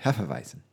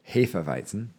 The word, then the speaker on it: Hefeweizen: Hefeweizen. – Yes. – Or as they would say, Hefeweizen, sorry. – Which is the correct pronunciation.